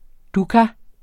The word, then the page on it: dukkah: [ ˈdukɑ ]; [ ˈduka ]